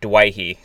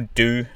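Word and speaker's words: Doueihi: And all the Lebanese teachers at the school, I've got to check this for me, they pronounce it Dwayhe. So there's no Dewey, it's Dwayhe, like Dwayhe.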